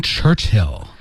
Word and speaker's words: Churchill: Oh, that's the one about William,、 Winston Churchill. – 好。 That's the one about, 我们来学一学、温斯顿丘吉尔的英文名怎么说 Winston Churchill.